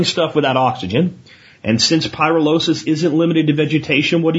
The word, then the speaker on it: pyrolysis: Okay, first, just to be really retentive, the word is pronounced pyrolysis.